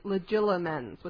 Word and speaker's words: Legilimens: Now, legitimens, it's that comes from the word legitimate, true. We're all pronouncing this wrong. It's legilimens with an L. – Legilimens? – Legila, reed. From the Latin legere, reed.